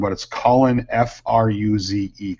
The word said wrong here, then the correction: Furze: Colin Furze.